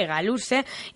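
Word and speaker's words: hegaluze: Se escribe con H, H-E-G-A-L-U-Z-E, e, egaluse, y significa aleta larga.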